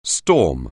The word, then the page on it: storm: anglais